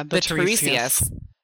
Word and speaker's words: Tiresias: Yeah, the Tiresias. Thank you. That makes sense because it's like a Greek word, so that follows.